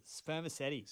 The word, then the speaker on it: spermaceti: What was it called again? Spermetti. – Spermaceti. – Spermaceti. – I'm probably saying that wrong. It's spelled S-P-E-R-M-A-C-E-T-I. So it's sounds either like spermaceti or spermaceti. Mm.